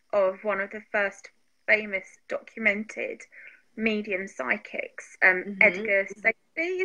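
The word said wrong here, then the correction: Cayce: Is it Safdie or Casey? – Edgar Casey, yeah.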